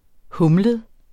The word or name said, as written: humlet